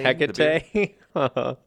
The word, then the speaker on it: Hecate: Demeter looked all over the earth for her daughter, even with the torches of the goddess Hecate. Is that how you say it? It's like Tecate. – Hecate.